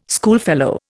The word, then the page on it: schoolfellow: Indian